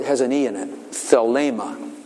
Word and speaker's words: Thelema: Thelema. T-H-E-L-E-M-A. T-H-E-L-E-M-A. T-H-E-L-E-M-A. So come in. – Is it like Thelma? – Huh? – Is it almost like Thelma? – Thelma?